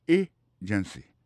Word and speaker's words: agency: Pour mot agency, hein, accent tombé sous premier syllabe là agency deux autres syllabes qui est derrière au faible